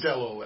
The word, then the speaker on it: castello: So it's south of Castillo is a castle. – Florence was where we'd go, like, on the weekend. – Castello, actually.